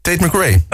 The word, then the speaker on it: Tate McRae: Tate McRae.